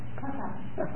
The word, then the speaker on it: papa: Papa. – Papa. Good. And the French is papa, accent on the last syllable.